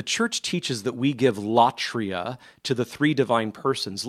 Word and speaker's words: latria: And latria, just how it sounds, it's spelled L A T R I A,